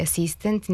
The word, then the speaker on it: asistent: A ti akcenti poput argument i asistent nisu nikakvi novi akcenti, već su akcenti koji su doneti još pre dva veka u vreme Vuka Karadžića i Đure Daničića, kada je propisano da silazni akcenti mogu stajati samo na prvom slogu višesložnih reči i na jednosložnim rečima.